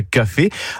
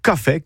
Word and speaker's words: café: Alors qu'en occitan, mais c'est un accent grave. Café, ben, je la mettais cette prononciation comme un catalan. En Italia, la langue occitane prononce café, donc comme en catalan et en italien.